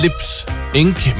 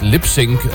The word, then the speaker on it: Lips Inc.: Foran mig på skærmen, der står der Lips Inc., men det skal faktisk udtales Lips Inc.,